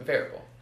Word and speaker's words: Vrabel: Pronounced Vrabel. So, I said that wrong, whatever, I'll admit.